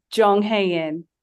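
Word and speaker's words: Jung Hae-in: Jong Hae-in.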